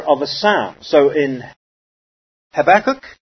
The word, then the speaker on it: Habakkuk: How do you pronounce that here? Do you say Habakkuk or do you say Habakkuk? Okay, Habakkuk. Habakkuk. In fact, that's correct as far as how it looks to be translated here, or pronounced, I should say.